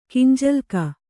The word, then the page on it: ಕಿಂಜಲ್ಕ: kinjalka